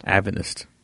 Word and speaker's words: Adventist: Adventist. – Is that not right? – I think right? – You pronounce the D, Adventist.